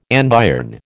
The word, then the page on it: andiron: American English